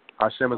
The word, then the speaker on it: Hashim: Hashim wow. – is spelled like has him, H-A-S-H-I-M, L-A-F-O-N-D.